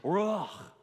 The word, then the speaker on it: ruach: He goes, Ruach.